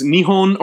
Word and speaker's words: Nihon: In Japanese, you pronounce Japan as Nihon or Nippon, depending on the grammatical context around it.